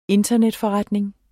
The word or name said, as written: internetforretning